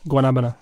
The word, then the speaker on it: Guanabana: Guanabana.